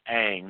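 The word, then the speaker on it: Ng: And it looks like the last name is pronounced Ang. You add an A, and it's A-N-G, so it would be Ang, Kim Ang.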